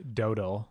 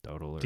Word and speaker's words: Dowdle: John Eric Dowdle. I assume that's how you pronounce his name. D-O-W-D-L. – Dowdle. – D-L-E. Dowdle.